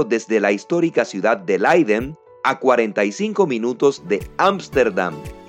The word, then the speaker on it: Leiden: Leiden, más o menos la pronunciación en español, Leiden.